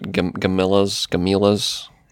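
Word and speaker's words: Gamilas: It's spelled weird. It's like Gamelons, but yeah, they pronounce it Gamillas.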